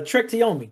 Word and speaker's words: Trek to Yomi: But uh, Trek to Yomi.